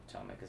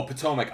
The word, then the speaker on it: Potomac: P-T-O-L. – Potomac. No. – Oh, Potomac. I always pronounced it Potomac. That's why I didn't know what you were talking about. – Oh. – I never heard it pronounced before.